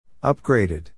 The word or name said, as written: upgraded